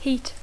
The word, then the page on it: heat: English